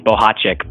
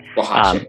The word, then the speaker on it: Bohochik: So in case she's listening or my mom's listening, I should say that it's pronounced Bohochik, but Bohochik.